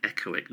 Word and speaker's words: echoic: Iconic? It's the word echo with IC at the end. That's not a word, is it? – Echoic, no.